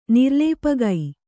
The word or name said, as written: ನಿರ್ಲೇಪಗೈ